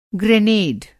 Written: ग्रेनेड

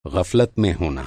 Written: ग़फ़लत

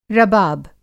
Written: रबाब

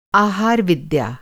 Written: आहारविद्या

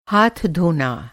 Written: धोना

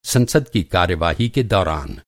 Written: संसद

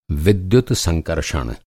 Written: संकर्षण

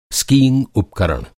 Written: स्कीइंग

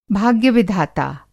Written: भाग्य-विधाता

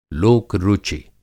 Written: लोक-रुचि

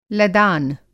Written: लदान

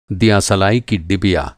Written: दीया-सलाई

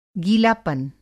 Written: गीलापन

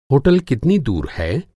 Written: होटल

